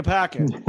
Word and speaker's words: Parker: Neely that I mispronounced Peter Parquois. – What? So they moved him to Boston? Peter Parker.